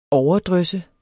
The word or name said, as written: OVERDRYSSET